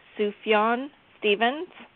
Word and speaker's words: Sufjan Stevens: Yeah, I guess his name is pronounced Sufjan Stevens.